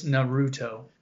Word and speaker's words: Naruto: And, like, he made fun of me for, like, how I pronounced Naruto the first time. I was like, Naruto? He was like, it's Naruto.